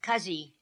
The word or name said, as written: Kazi